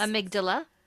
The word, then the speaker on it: amygdala: Amygdala. – Thank you. Amygdala hijack.